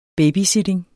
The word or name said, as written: babysitting